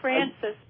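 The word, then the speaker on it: Frances: It's Francis, but I'm Italian.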